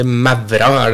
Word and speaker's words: Mavra: han holder til på Maura. Eller Mavra det vel strengt at det uttales Gjerdrum oppi der, ikke ja.